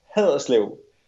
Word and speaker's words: Haderslev: Here I'm going to reveal my non-Denmark knowledge, but how do you pronounce your hometown is Haderslev? Haderslev? How should I pronounce it? – It's pronounced Haderslev.